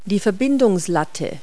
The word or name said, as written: yoke